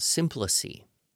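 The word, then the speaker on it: symploce: If you were to use epistrophe and anaphora at the same time, you would get what is called symplice. And that's S-Y-M-P-L-O-C-E. That's the way I believe it's pronounced. But again, people could argue.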